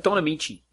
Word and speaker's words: Don Ameche: Am I pronouncing that right? – I think it's Don Ameche.